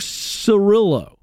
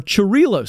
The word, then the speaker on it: Cirillo: And this was a productivity hack that was kind of suggested in the 1980s, developed from a guy by the name of Francesco Cirillo. Or Cirillo, sorry. Thank you for the pronunciation.